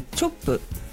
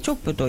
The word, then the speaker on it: 首都: はい、 5 月の 30日土曜日、はい、池袋にあるチョップ、はい、首都と書いてチョップという、ライブハウスで、うん、盲目ダイアリーさんのイベントに出演させていただきますので、はい、よろしくお願いいたします、はい、ぜひお越しください。